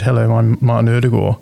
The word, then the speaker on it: Ødegaard: You don't have the D in there at all. – Martin Ødegaard, if you're actually like Norwegian. That's so I would say Ødegaard, but that's wrong. Ødegaard. – You sort of soften the D.